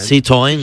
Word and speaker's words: Citroën: No, la pronunciación. – Citroën. – Citroën. – Sí, muy bien.